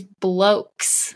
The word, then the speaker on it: blokes: These blokes. – Oh, I was like, wait, did I say that right? – Because that's like because referencing. That's, yeah. – It's like, wait a second. I said that wrong.